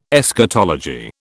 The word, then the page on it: Eschatology: British English